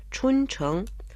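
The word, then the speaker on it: 春城: Круглый год город в цветах, поэтому его называют городом весны – Чунчэн Чун означает «весна», произносится в первом тоне, а Чхэнг – это город, произносится во втором тоне.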